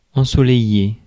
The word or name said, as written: ensoleillé